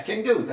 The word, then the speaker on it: Chengdu: Chengdu? Oh. – Yeah, Chengdu.